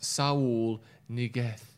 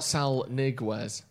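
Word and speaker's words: Saúl Ñíguez: Just want to let you know that Sal Niguez is pronounced Saul Niguez. – Okay. – Not Sal Niguez.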